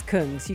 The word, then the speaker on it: Kungs: And he went, "Hun, it's Kungs. You can't say it wrong. Stop saying it wrong." – Kungs.